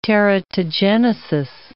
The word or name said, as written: teratogenesis